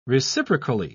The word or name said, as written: reciprocally